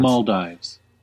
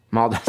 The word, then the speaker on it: Maldives: No, and you got three people in this room all separately looking up how to pronounce Maldives. – Maldives.